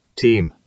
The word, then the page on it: teem: British English